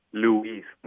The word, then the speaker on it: Luis: it'll be Luis. There's an emphasis on the U, mm-hmm. and Spanish is just Luis.